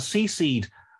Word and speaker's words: C-Seed: C-Seed, letter C, seed spelled like a seed, the name of the company, which is, I think, an awful name for a company.